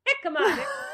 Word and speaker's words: jicama: Who knew that hemica, am I saying that right? Hemica. How do you say that? – Jicama? – Nope. Jicama. I say, loser.